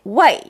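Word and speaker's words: 와이 (喂): 사람을 부를 때는 여보세요. 반드시 와이 네. 사성으로 할 수 있어요. 네. 전화를 받을 때는 이성으로 발음할 수 있습니다. 와이.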